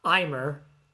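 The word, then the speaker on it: Aimer: This is the second one that we're talking about now, and it's called Daydream, and it's performed by Imer, who is actually pronounced as M-A. It's A-I-M-E-R, but it's pronounced as M-A, and I'm guessing it's a French thing.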